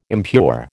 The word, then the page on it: impure: American English